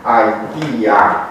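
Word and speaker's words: Aitia (αἰτία): Aitia. Da? Acest ai are soarta lui ai din latină, pe care deja am atins-o și probabil că ați atins-o și la alte cursuri. Îl scriem ai, îl citim ai, adică îl scriem ai, îl citim ai, și în latina târzie și apoi în trecerea către limbile romanice, este absolut evident că el se pronunța samonoftongatotar și se pronunța pur și simplu e.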